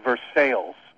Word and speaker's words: Versailles: The locals pronounce it Versailles, but it's actually written like the city in France, Versailles.